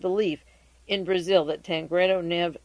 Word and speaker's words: Neves: T-A-N-C-R-E-D-O, Tancredo Neve, N-E-V-E-S, the S is silent.